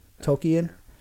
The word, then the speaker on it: Tolkien: It's Tolkien.